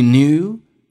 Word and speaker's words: knew: If you knew that your experiences 这个单词，美式发音当中第一个 k 是不发音，第二个是中间有一个 a 会被 r 所吸收，因为它有这样的一个规定，a 跟 r 组合在一起，在美式发音当中 a 是不发音的，就变成 experience。